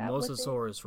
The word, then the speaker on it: Mosasaurus: Is it a Montesaurus roll? – Oh, the Mosasaurus roll.